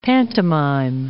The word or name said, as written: pantomime